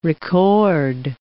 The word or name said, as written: record